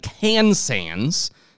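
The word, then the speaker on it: Kansan: The AT-11 Kansans, not to be pronounced Kansas, where they were actually made.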